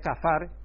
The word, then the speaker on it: kafar: La palabra ahí, expiación, es kafar. Kafar, porque la pronunciación es un poco diferente, pero es kafar, como se podría entender.